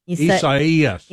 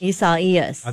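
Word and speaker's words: Isaias: Do you emphasize the second E? Esaias. – Esaias. – I think that's right.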